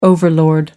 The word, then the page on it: overlord: American English